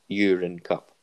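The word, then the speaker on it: Uhren Cup: As in U-R-I-N-E. – It's spelled a bit differently, but it is pronounced. – We'll keep the magic urine alive. – Cup. Yeah. U-H-R-E-N, so Urine Cup. That's how you say it, isn't it? – Yeah.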